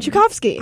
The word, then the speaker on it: Tchaikovsky: Yeah, Tchaikovsky.